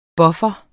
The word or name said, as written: PUFFEREN